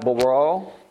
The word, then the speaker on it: bara: So the word create in the Hebrew is pronounced something like bara, and it means to create, to make, creator.